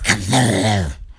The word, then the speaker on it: Cthulhu: If you pronounce it properly, it's Cthulhu.